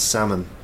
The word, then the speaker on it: salmon: It's a silent L. You don't say salmon, it's salmon.